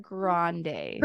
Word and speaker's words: Grande: Ariana Grande. – Pronunciations have never been your strong suit. – So. No, they haven't. It's not even that hard of one. Why do I want to add a W? Grande.